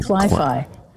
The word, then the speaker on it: cli-fi: One of the terms I came across that you use is something, I think I'm pronouncing it right, cli-fi, like as in cli-fi. That's a recent term, although it's used an awful lot right now.